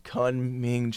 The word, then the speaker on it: Kunming: Can you talk about your experiences in Kunming, China? Did I pronounce that correctly again? – You did.